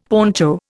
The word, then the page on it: poncho: Indian